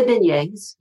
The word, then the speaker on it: beignets: What I always say it wrong. Is it beignet? Those like donut kind of things. Did I say it? – The beignets. Yeah.